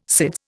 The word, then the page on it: SIDS: Indian